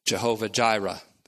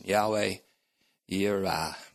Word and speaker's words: Yahweh Yireh: It is said in a more vulgar way, Jehovah Jireh, but that's not the real pronunciation. Yahweh Yireh.